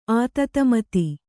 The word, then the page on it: ಆತತಮತಿ: ātatamati